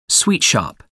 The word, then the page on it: sweet shop: American English